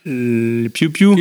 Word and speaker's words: Piou Piou: How do you pronounce it? Poi poi poi poi. The little the children Piou Piou. Piou Piou.